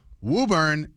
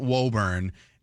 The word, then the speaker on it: Woburn: For example, Woburn, it's not Woburn.